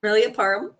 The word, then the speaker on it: Permelia Parham: It's Permelia Parham.